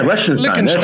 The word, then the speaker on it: Liechtenstein: Yeah, Liechtenstein. – There you go. – Yeah. But yeah, Argentina, Brazil, and Colombia is in the IIHF Men's Development Cup with Greece, Ireland, Portugal, and Liechtenstein.